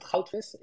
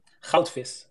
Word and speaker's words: goudvis: Yeah, it's you pronounce it goudvis. It's Dutch and it means goldfish. – Okay, I'm not even going to try to. Can you pronounce it for me again? – Goudvis. It's a hard G.